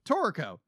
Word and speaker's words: Toriko: Toriko.